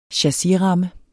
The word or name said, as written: chassisramme